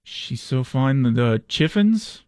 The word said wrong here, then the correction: Chiffons: How about the chiffons, dude? The Chiffins. The Chiffins.